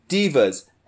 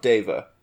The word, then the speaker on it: deva: All right, so, guys, I have planetars, and I have divas. And according to the internet, in multiple places, it is pronounced diva and not deva.